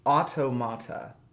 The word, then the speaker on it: Automata: And Nier is spelled N-I-E-R. Yeah. Not like near and far, but N-I-E-R. – Yes. – Automata. Like automation, but automata.